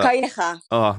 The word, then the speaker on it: Calleja: Calleja, how's that? Calleja. – Oh, there you go.